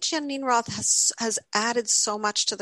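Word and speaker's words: Geneen Roth: And I found the author, I believe her first name is pronounced Janine Roth. – Janine Roth.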